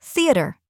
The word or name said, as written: theatre